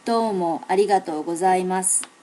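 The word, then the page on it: domoarigatogozaimasu: native Japanese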